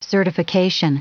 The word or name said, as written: certification